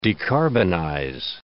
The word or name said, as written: decarbonize